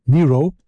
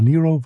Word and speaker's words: Nyro: Gut, der gute alte Mond hatte gefragt nach Laura Nyro. Ich habe die auch jahrelang Nyro genannt oder sowas, aber Nyro wird sie tatsächlich ausgesprochen.